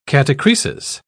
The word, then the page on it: catachresis: British English